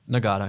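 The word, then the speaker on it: Nagata: Ja, dit was eh, Michael Nagada, zeg ik dat goed zo? Nagada, ja. Ja.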